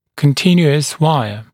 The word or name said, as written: continuous wire